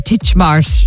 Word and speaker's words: Titchmarsh: Is that how you pronounce his name? Titchmarsh. – Yeah, Alan.